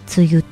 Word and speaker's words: 梅雨: 梅の雨と書いて梅雨と読む。